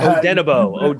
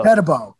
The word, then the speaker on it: Odenigbo: You bring in Odenigbo to come in. I think that's how you pronounce it. But I Odenigbo. Odenigbo. – Odenigbo.